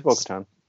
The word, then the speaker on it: Bo-Katan: Yeah, it's Bo-Katan.